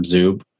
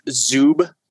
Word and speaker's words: Zub: I always went with Zub. Okay. – But there's no umlaut on it or anything that would make it suggest that it's a long U.